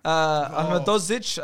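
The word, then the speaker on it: Ahmedhodžić: My boy Anel Ahmedozovic. Ahmedozic, as it's pronounced, had a very good game.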